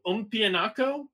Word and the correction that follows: Upamecano: I think it's Upamecano. Upamecano.